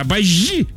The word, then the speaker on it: обожди: Во-первых, не обожди, а обожди, говорят.